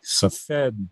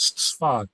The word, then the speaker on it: Safed: And now, again, I'm talking about a place called, and it looks in English like Safed, but I pronounce it as we do in Israel as Sfat.